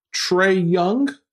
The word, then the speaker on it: Trae Young: Trey Young. – Look at you, T-R-A-E. You got the pronunciation right.